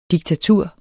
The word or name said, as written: DIKTATURET